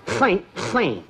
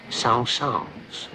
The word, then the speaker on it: Saint-Saëns: Saint-Saëns. Faint, faint. songs. Faint faint. Songs. It's pronounced faint faint. It is pronounced songs.